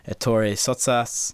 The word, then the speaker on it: Ettore Sottsass: It includes the likes of Peter Blake, Richard Hamilton, Roy Lichtenstein, Andy Warhol, Ettore Sottsass.